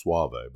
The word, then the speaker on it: Soave: which is Soave, by the way, and that's pronounced S-W-A-H-V-A-Y.